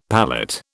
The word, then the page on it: palette: British English